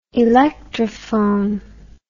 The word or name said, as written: electrophone